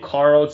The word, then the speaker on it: Carl: And you can Google my name, Carl Zha. That's spelled with a C, C-A-R-L, last name Z-H-A, Zebra Henry Apple.